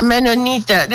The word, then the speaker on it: Mennonieten: Mennonieten, zeg ik het goed? Mennonieten? Ja, toch? – Ja, Mennonieten.